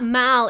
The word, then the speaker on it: Mall: It's spelled M-A-L-L, but the way you pronounce it, if you're like English people, is the Mall.